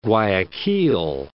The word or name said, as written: Guayaquil